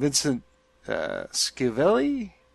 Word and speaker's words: Vincent Schiavelli: Vincent Schiavelli? I'm not gonna pronounce his last name. Yeah, that sounds about right.